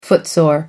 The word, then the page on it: footsore: American English